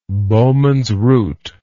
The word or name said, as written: Bowman's root